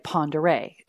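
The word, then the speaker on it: Pend Oreille: Well, after we were there for 3 days, we went to Farragut State Park in Lake Ponderay, and it's spelled Pendorielle. It looks like Panned Oriole.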